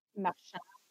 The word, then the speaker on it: Marchand: Marchand. – Sorry. – Guys, that's how we're going to be pronouncing his name, except for Jeremy, I presume, because, you know, Marchand sounds like a French name.